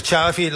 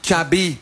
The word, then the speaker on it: Xavi: Hij zegt Xavi like in chocolate. – Xavi like in chocolate. Dus niet Xavi.